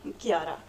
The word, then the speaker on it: Chiara: Chiara.